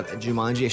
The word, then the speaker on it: Jumanji: But maybe Jumanji? Did I say that right? – Jumanji, sure.